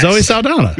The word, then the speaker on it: Zoe Saldana: Zoe Saldana.